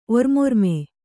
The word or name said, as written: ಒರ್ಮೊರ್ಮೆ